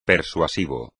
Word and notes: persuasivo: Spanish

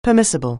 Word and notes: permissible: anglais

